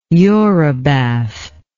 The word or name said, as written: eurybath